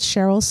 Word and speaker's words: Sherrill: Why do I keep saying Sherrill? – Because it's spelled Sherrill. Very weird. Her name is spelled S-H-E-R-R-I-L-L. – Yeah. Someone. And it's Sherrill.